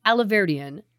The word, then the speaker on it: Alahverdian: In 2019, Nicholas Aliverdian, is that how you say it? – You're doing great.